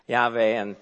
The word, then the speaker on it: Yahweh: Well, a lot of times it's pronounced Yahweh, but the third letter in that word actually is normally pronounced with a V sound, Yahweh, and even more common is kind of the transliteration Jehovah.